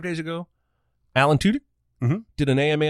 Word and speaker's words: Tudyk: Because he spelled it T-Y. So T-Y, like T-I-C-K. Oh, yeah. – Tudyk. All right.